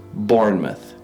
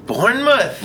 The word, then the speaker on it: Bournemouth: Pronunciation courtesy of the official Oxford English Dictionary is Bournemouth. – Bournemouth. – Mm. Bournemouth.